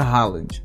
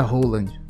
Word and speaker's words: Haaland: Ele falou o seguinte: se você estiver fora da Noruega, se pronuncia Haaland. Se você estiver dentro da Noruega, se pronuncia Roland.